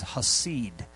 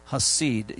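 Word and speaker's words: chesed: The word loving kindness is hasid. Hasid. You spell it. Let me give you that spelling. That spelling is C-H-E-S-E-D. C-H-E-S-E-D. Hasid is how it's pronounced, and the number is 2617.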